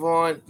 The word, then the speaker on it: Vaughn: So it's Ethan Vaughn. The last name is spelled V-A-U-G-H-N.